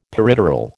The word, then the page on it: peripteral: American English